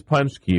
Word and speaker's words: paczki: Can you spell Punchki? – Yes. – Because that's how you pronounce it. – It is Punchki. – Don't look it up. – Oh, I have to. – You, no. – I'm cheating right now. – P-A-C-Z-K-I.